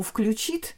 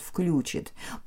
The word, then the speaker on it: включит: ударение имеет на второй слог должно падать. Но, конечно, есть некое движение в языке, uh-huh. в разговорной речи, которая представляет собой лабораторию языка, мы встречаем и второй вариант «включит».